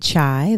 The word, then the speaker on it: Chi: Well, as always, you can find me at Chai, that's C-H-I, no A, the letter T, which is my middle initial.